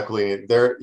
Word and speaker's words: Aquilini: From what I heard this morning, the owner, one of the owners that I can never pronounce their last names, but Aquilini or whatever it is. – Aquilini? – Yeah, Aquilini.